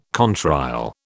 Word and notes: contrail: British English

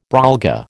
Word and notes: brolga: American English

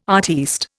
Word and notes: artiste: Indian

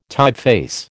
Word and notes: typeface: American English

